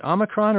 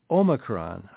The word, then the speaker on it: Omicron: Is it Omicron or is it Omicron? I don't know how you would say it, but everybody's saying it different ways. Well, you know what? You can say it either way. When I was taking classes in New Testament Greek, preparing for the ministry, we pronounced it Omicron to distinguish it from the other Greek O letter, Omega, but I think that's just a scholarly convention.